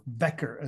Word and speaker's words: Wecker: And today I have the distinct honor and pleasure to have Andreas Wecker, or Wecker as he would pronounce it, with me.